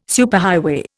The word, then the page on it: superhighway: Indian